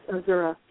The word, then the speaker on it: Azura: It is Azura Wisdom.